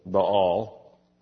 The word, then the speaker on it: Baal: The followers of Baal, often referred to by some preachers as Baal, it's actually Baal. You know, if you're going to talk about an idol, you ought to at least pronounce his name right.